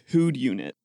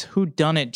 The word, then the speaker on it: whodunit: The title on the box just says Hood Unit. Okay, can you spell that? W H O D U N I T. – Oh, it's Who Done It.